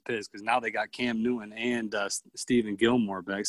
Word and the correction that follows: Stephon: Stephen, right? Stephen Gilmore? Stephen? – Stephon, I think is maybe how you say it.